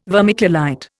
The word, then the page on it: vermiculite: Indian